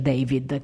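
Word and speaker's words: D4VD: con una strana traslitterazione del suo nome, perché è D4DV. D. Si legge David, così ha specificato lui,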